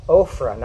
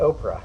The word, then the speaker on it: Ophrah: Verse 11 now the angel of the Lord came and sat under, under the terebinth tree, terebinth tree, which was in Ophrah, not Oprah. I say that wrong every time.